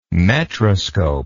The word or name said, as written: metroscope